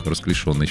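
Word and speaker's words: расклешённый: В общем, придется принять, что ударение на третий слог расклешенный считается не... верный. Представляете? Правильно говорить. Вот поставьте букву «ё», и все будет понятно.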